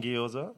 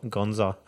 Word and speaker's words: gyoza: Prawn gyoza. – Yep. And they were fine. – Oh, is that how you pronounce it? I was pronouncing it Gonza.